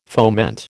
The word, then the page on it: foment: American English